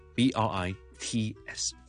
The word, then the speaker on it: Brits: 好啦，再俾多兩個字大家，一個咧就係 Brits B R I T S 先 T 後 S Brits。